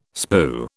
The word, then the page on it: spue: British English